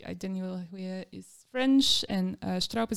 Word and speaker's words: Huillet: Um, I'm not sure if I pronounce Huillet.